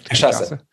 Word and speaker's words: cachaça: I was introduced to, and I want to say this, pronounce this correctly, cachaça. Is that right? – Cachaça.